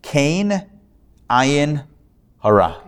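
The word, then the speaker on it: kein ayin hara: Kenahara, or Kenahara, which actually is a bit of a quick pronunciation of three words. It comes from a Yiddish phrase, Kain Ayin Hara.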